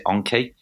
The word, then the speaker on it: Anki: A company called Anki, I think you guys in the US pronounce it Anki. – Yes, yes.